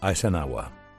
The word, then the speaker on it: Eisenhower: Se pronuncia Eisenhower.